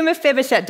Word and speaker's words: Mephibosheth: His kindness to Mephibosheth.